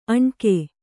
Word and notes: ಅಣ್ಕೆ: aṇke

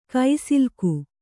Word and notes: ಕುಸಲಕ್ಕಿ: kusalakki

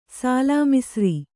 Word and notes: ಸಾಲಾಮಿಸಿರಿ: sālāmisiri

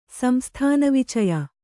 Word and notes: ಸಂಸ್ಥಾನವಿಚಯ: samsthana vicaya

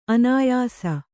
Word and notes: ಅನಾಯಸ: anāyasa

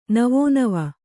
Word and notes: ನವೋನವ: kannada

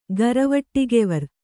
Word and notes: ಗರವಟಿಗೆವರ್: kannada

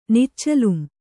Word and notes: ನಿಚ್ಚಲುಂ: Kannada

